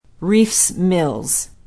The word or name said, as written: reifs mills